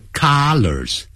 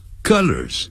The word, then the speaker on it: colors: 颜色不要念成 colors 不是 R 的音是鹅的音肚子鹅的鹅 colors colors 那是 C-O-L-L-A-R 加个 S